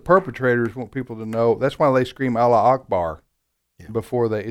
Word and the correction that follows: Allahu Akbar: Is that how you pronounce it? – Allahu Akbar.